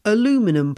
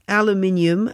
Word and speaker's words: aluminium: Yes, here in the UK, we do pronounce aluminium as aluminium because we spell this word with two I's, whereas in North America, it's only spelt with one I.